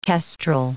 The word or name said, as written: kestrel